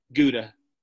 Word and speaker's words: Gouda: And I remember we were in a town called Gouda, which is Gouda, in the Netherlands.